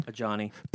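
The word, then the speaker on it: Adjani: Ajani.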